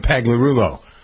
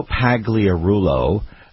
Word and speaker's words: Pagliarulo: Joe Pagliarulli. I never pronounce it. Pagliarulo, right? – Yeah, it's Joe Pagliarulo.